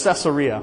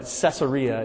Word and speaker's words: Caesarea: It's Caesarea, by the way, if you're looking at that going, how do I pronounce that? Caesar is the term for Roman emperor, you know that. There was a town kind of founded in the honor, and Pastor Bob's actually been to this place in Israel. You can go to Caesarea today, but it was named in honor of Caesar Augustus, but Caesarea is the way you pronounce it.